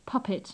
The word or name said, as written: puppet